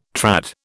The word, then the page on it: trad: British English